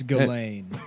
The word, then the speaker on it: Ghislaine: What his girl Ghislaine or whatever the fuck? – Yeah, yeah, it's Ghislaine. They don't. Yeah, none of the you don't pronounce any of the letters.